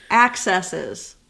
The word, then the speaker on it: accesses: You're sitting putting the wrong emphasis on the wrong syllable. – Accesses. – There you go.